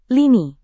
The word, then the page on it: lini: indonesian